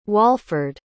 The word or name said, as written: Walford